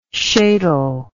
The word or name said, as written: sheitel